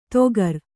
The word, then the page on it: ತೊಗರ್: togar